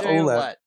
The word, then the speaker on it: Ouellette: Ouellette, how do you pronounce that? – AJ yeah, Ouellette. – Yeah, Ouellette. Ouellette. Okay, cool.